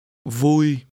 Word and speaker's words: vui: Vui.